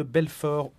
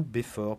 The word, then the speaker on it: Belfort: Sans oublier les 24 années durant lesquelles vous avez dirigé la ville de Belfort ou Béfort, puisque le L n'est pas obligatoire dans la prononciation.